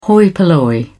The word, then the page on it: hoi polloi: British English